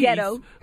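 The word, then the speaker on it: ricotta: I just saw this white man next to me order a pizza with anchovies, pineapple, and how you pronounce it? Regatta cheese. – Rigetto.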